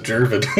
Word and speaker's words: Gervon: You keep hitting that hard G like the man's name isn't Gervon.